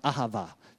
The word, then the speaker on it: Ahava: They say ahava, but it's actually pronounced ahava, just so you know.